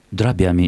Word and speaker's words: дробями: Кристина вот нам пишет, как правильно говорить, дробями или дробями ударением? – Дробями, и тут вариантов быть не может.